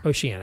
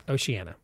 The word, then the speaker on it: Oceania: Oceania. – Thank you. – Pretend Oceanic Flight 815. – Thank you. – Yes. – 5, 8, 12. Thank you. Oceania, right? – Oceania.